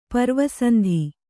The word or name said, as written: ಪರ್ವಸಂಧಿ